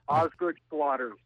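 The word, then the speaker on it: Osgood-Schlatter: Osgood Schlatter's disease. – Yes. – A very common problem. – Yes, it is, and you pronounced it very well.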